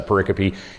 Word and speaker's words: pericope: And so we get together on Wednesday and we go through each one of these what's called a pericope looks like it's spelled pericope, but it's pronounced uh, pericope.